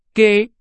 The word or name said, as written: 给